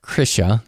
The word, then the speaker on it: Krisha: It's spelled like Krisha, K R I S H A, but it's pronounced Krisha.